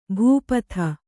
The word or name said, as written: ಭೂಪಥ